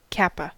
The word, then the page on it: kappa: American English